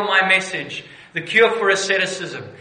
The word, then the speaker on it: asceticism: Asceticism. Have I pronounced it correctly? There we go. The good doctor said yes. Asceticism.